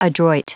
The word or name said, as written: adroit